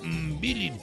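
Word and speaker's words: Mbilli: Cuando Saúl El Canelo Álvarez se enfrente a Cristian Mibil. Se pronuncia Mbilin. Ah, sí, cierto. Mbilin.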